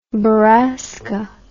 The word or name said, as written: borrasca